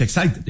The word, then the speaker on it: Exide: Se escribe Exide, se pronuncia Exide.